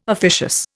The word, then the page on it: officious: Indian